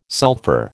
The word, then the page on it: sulphur: American English